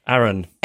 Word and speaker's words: Arran: And when I moved, my dad, who lives on the Isle of, is that pronounced Arran? – Arran.